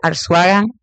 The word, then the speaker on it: Arzuaga: Instagram, Facebook, Lizelle Arzuaga, Lizelle, es como se escribe mi nombre, Arzuaga con Z,